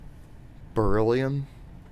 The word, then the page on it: beryllium: American English